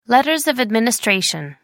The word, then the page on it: letters of administration: American English